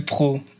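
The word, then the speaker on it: Prot: Ouais, je m'appelle Pro. Et ça s'écrit PR2OT. Alors, je vous laisse faire le calcul mental. Mais ça ressemble à Prout, effectivement.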